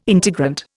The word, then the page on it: integrant: Indian